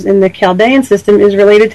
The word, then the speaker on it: Chaldean: One of them is called the Western Pythagorean system, and the other is the Chaldean system. I hope I'm pronouncing that correctly. – That's correct, yeah.